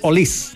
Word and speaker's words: Olise: Aquí, ahora me golpeaste con la pronunciación Olis. No Olise.